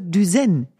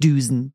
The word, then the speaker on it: Düzen: Mein Name wurde ja auch über, weiß ich nicht, 40 Jahre falsch ausgesprochen und ich habe es so hingenommen. Es hat ganz lang gedauert, bis ich gesagt habe, Düsen, nicht Düsen.